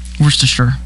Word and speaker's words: Worcestershire: Worcestershire way down. – I'm not saying it anymore. Worcestershire.